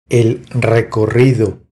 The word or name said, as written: El recorrido